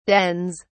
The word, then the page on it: dens: British English